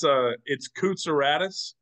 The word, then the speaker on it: Kootsouradis: So it's Kootsouradis. Last name is Greek. Very proud of being Greek. The easiest way to remember how to say it, how to spell it, how to do everything with my last name is break it into three parts. You got Coot, you got Sour, and you got Addis.